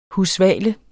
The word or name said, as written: husvale